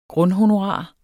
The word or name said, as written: grundhonorar